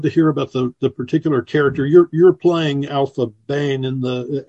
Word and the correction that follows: Aphra Behn: Aphra Bane, who was the first published female playwright in England that we know of.